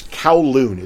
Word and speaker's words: Kowloon: It's Kowloon. It's K-O-W-L-O-O-N.